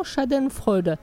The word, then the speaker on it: Schadenfreude: Alors, je vais essayer de le prononcer comme il faut, donc pour sa chronique, les moments Schadenfreude.